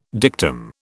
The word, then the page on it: dictum: British English